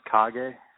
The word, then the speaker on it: Kage: Now, for listeners, you have an independent comic publishing company called Cage Comics. Is that correct? – Yeah, it's pronounced Kage, actually. – Oh, Kage. Okay. – It's a Japanese word for shadow.